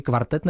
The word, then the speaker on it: kvartet: Abych to vyslovoval správně, říkáte si Krása kvartet, česky kvartet nebo anglicky quartet, takhle s tím O? – Český kvartet.